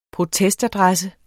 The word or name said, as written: protestadresse